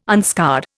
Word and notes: unscarred: Indian

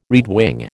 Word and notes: redwing: American English

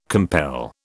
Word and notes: compel: British English

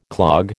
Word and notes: clog: American English

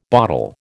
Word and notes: botel: American English

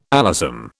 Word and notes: alyssum: British English

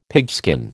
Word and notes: pigskin: American English

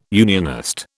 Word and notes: unionist: British English

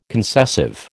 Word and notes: concessive: American English

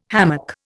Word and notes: hammock: Indian

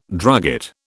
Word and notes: drugget: British English

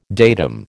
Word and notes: datum: American English

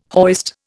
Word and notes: hoist: Indian

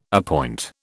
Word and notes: appoint: British English

